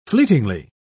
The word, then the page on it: fleetingly: British English